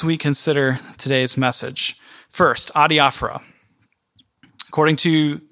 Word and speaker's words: adiaphora: Number one, you need to know the word adiaphora. Adiaphora.